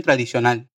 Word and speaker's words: tradicional: Una conmemoración tradicional. Una conmemoración tradicional. Atención a la pronunciación acá, ¿eh? Tradicional tiene esa L final bien marcada, que no se vuelve una U como en portugués.